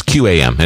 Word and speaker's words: QAM: I say CAM. – Well, it would be a C then. It's QAM. Anyway, go ahead.